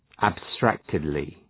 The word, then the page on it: abstractedly: æb’stræktıdlı